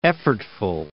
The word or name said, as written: effortful